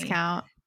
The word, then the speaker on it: Viscount: However, I do thank this book for teaching me how to pronounce the word Viscount, because definitely Viscount.